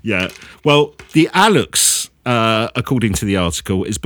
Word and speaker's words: Alux: His article says, in Maya culture, the Alux, I think it's Alux, A-L-U-X-E, but I think it's pronounced Alux. – That's how I'd say it, yeah.